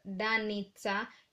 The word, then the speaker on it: Danica: Aaron Rodgers from Green Bay Packers quarterback, and Danica Patrick was an ex-professional driver. And I want to remind you guys that Danica, actually it's a Serbian name which is pronounced as Danica, and it means a star called Venus.